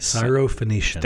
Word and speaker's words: Syrophoenician: Syrophoenician. – Thank you very much. You're just much better at saying the long words, except for perfect. You can't say that one. So, Syrophoenician by birth, and she begged him to cast the demon out of her daughter.